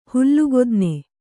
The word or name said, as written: ಹುಲ್ಲುಗುದ್ನೆ